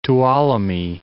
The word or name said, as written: Tuolumne